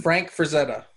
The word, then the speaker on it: Frazetta: Like, he painted Metalshark Bro in, like, the Conan-style Fran Freshetta. Is that how you pronounce his name? – Frank Frazetta.